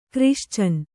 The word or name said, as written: ಕ್ರಿಶ್ಚನ್